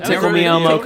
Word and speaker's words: Tickle Me Elmo: The emphasis on the me. Tickle me, Elmo. Tickle me.